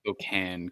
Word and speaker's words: Spokane: I think that was going on in Spokane, right? – It's pronounced Spokane. – Is it? – Yes.